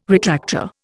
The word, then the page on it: retractor: Indian